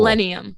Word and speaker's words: Illenium: Illenium? – Cool. Illenium.